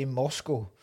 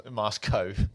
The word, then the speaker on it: Moscow: Another thing that annoys me, and this doesn't just annoy me in this film, it annoys me in every film, how Americans say Moscow. – Moscow. Yeah. – Thanks very much for your hospitality, Moscow. – Yeah. – Fuck off. – Yeah, it's Moscow.